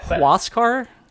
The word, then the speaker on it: Huascar: For daring to complain about the universal DH in an NL park, I just got crushed by Huascar Ulloa. Yeah. Or Haskar. Yeah. I can't. Yeah. Cut that. – Huascar? Yeah. – It's pronounced like NASCAR with a WH sound, I think I saw.